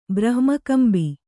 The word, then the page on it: ಬ್ರಹ್ಮಕಂಬಿ: brahma kambi